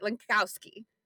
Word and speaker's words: Lenkowski: Um, w- w- w- say your last name so just make sure I get it right Lenkowski. Lenkowski. Yeah, pretty. It's it, it's just like it it looks, but it does always trip people up.